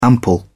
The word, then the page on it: ample: British English